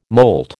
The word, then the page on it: moult: American English